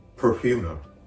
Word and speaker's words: perfumer: パーフューマー、調合師はイ語で perfumer もう一度発音します。